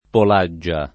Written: Polaggia

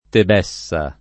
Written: Tebessa

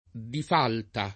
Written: difalta

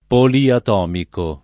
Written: poliatomico

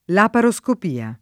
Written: laparoscopia

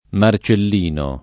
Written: Marcellino

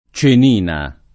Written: Cenina

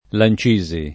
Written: Lancisi

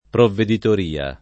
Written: provveditoria

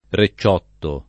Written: recciotto

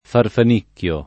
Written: farfanicchio

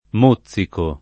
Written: mozzico

